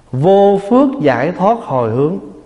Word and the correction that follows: vô phược: Xin lỗi, đọc là vô phược.